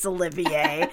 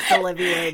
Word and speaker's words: Olivier: Everyone knows how to pronounce Olivier, as in Laurence Olivier, the actor. – Yeah, you know what?